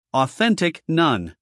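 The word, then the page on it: authentic: uk ɔːˈθentɪk; en-us ɔːˈθentɪk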